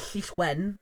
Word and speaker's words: Llyswen: The Welsh way of putting it is Llyswen, or what that means.